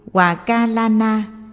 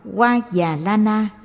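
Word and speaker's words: Hòa Già La Na: Hòa Ca La Na, cũng đọc là Hoa Già La Na, dịch nghĩa là Thọ Ký.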